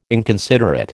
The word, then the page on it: inconsiderate: American English